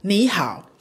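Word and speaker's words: ni hao (你好): Fácil, otra vez, ni hao. ¿Lo pronuncio bien? – Ni hao, sí.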